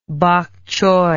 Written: pakchoi

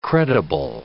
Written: credible

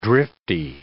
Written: drifty

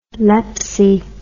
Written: lepsy

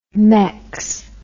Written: Mex

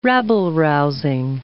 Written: rabble-rousing

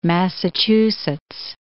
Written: Massachusetts

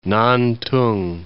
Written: Nantong

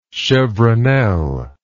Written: chevronel

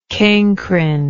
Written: cancrine

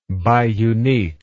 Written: biuniquely

